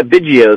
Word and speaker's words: Biggio: Yeah, Biggio is fine.